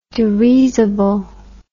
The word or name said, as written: derisible